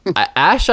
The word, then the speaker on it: Ashe: Sonic and the Black Knight, you voiced Ashe, I believe it's pronounced, in League of Legends.